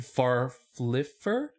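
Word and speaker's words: Farffler: In 1655, a 22-year-old watchmaker named Stephen Farfliffer? I'm butchering this dude's name. – You nailed it. That's how you say it.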